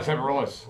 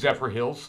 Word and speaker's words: Zephyrhills: It's not Zephyrhills. – Oh, now you know. – you're mispronouncing it. – How is it pronounced? – Zephyrhills.